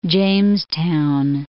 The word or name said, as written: Jamestown